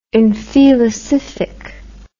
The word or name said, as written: infelicific